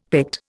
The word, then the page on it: Pict: Indian